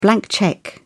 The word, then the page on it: blank cheque: British English